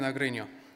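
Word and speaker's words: Agrinio: And he was born in Agrino. Did I say it right, Ange? Pronounce it correctly? That's good, yeah.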